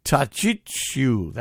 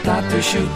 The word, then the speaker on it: Tatusiu: Tachichu. That's wrong. Tatuchu. I don't know. I had it. I swear I had it. Anyway, here's Ed Gucka's Polish Canadians. – Tatusiu, tatusiu, ja śpiewam dla ciebie, bo życie moje zacząłeś.